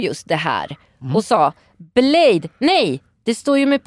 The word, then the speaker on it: Bladé: Precis, eller då Blade som det ska uttalas. Men det stavas alltså Blade och så är det E med två prickar.